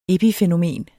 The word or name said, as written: epifænomen